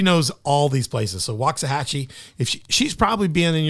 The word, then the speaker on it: Waxahachie: Waxahachie? – Waxahachie. – Waxahachie.